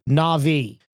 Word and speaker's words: Na'vi: If you have never seen this movie, you do not know how to pronounce this word. It is Na'vi. Na'vi.